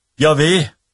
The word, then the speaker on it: Yahweh: På hebraisk består navnet av fire konsonanter, J-H-V-H, noe som gjorde at det var umulig å uttale det. Yahweh er det nærmeste vi kommer.